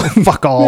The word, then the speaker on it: Facal: I'm going to mispronounce all these names, but it's Manuel Facal. – Facal? Facal. – Facal. – Manuel Facal.